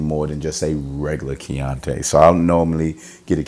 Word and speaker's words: Chianti: Not a Chianti, but a Chianti. It's spelled accordingly. C-H-A-N-T-I. Let's do it one more time because I'm an idiot. C-H-I-A-N-T-I.